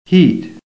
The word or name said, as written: HEAT